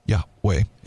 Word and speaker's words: Yahweh: But you take them away, and it's the word Yahweh. And we know it's that because everywhere in the Bible, it would never be pronounced Yeho, because there's no yeah. I mean, Yahweh is always that way.